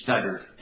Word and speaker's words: Studdard: It's Ted, T-E-D, and last name is Stoddard, S-T-U-D-D-A-R-D.